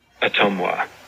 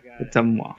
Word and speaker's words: Ottumwa: And this is how you're supposed to pronounce it as a native Iowa. Okay, maybe I should turn my volume up here. – Ottumwa.